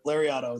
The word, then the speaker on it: Lariato: And I was going to touch on your promotion, your Lariato Pro Wrestling, I believe, right? Is that how you say it? – Yep. Lariato. It's Japanese clothesline.